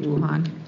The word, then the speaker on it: Wuhan: and so anyone traveling to Wuhan is mandatory, am I saying that right, Wuhan, China, mm-hmm.